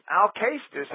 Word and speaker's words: Alcestis: Alcestis actually is the correct Greek pronunciation.